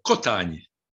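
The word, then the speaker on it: Kotányi: La prononciation exacte, c'est Cotani.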